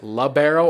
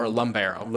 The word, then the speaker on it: libero: Is it libero or lumbero? – Libero. – Libero. – No libero. M. – Okay. No M. No M in it.